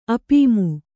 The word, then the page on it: ಅಪೀಮು: apīmu